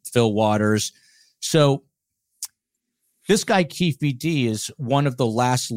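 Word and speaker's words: Keffe D: It's spelled different. The AP spells it with two F's and one E, so Kefe D, but it's pronounced Keefe D.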